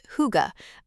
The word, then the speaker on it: hygge: My Danish education began with learning about hygge, pronounced hygge, a concept so central to Danish identity, it's both a verb and adjective.